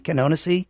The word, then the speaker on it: Canonici: Canonici, did I say that right? – Nailed it.